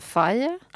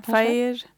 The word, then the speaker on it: Faye: Hur uttalar man efternamnet, tror du? – Stavas F-A-I-E, Faye. – Ja, Faye.